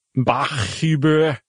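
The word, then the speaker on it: Bachhuber: Bachuber. – B-A-C-H, which is fine. That's clearly Bach. And H-U-B-R. You just don't often see two H's side by side. – No. – So anyway, Kevin Bachuber.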